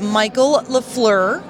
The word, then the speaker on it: Michael LaFleur: You guys, I'm excited to be talking to Mr. Michael LaFleur.